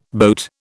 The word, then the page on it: boat: British English